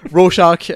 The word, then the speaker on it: Rorschach: as like Rorschach, I can't freaking pronounce his name, Rorschach, yeah, being, there you go.